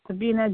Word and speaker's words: Sabina: Sabina D.